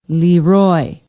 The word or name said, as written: leroy